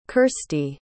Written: kirsty

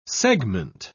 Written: segment

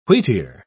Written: wheatear

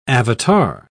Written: avatar

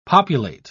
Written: populate

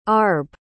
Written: arb

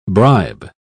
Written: bribe

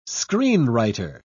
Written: screenwriter

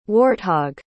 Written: warthog

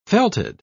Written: felted

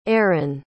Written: erryn

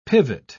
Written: pivot

